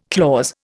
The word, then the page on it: clause: Indian